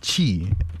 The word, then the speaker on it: Qi: Tech jargon of the day is QI. QI, what we will normally pronounce as Qi, which is the wireless standard for charging devices.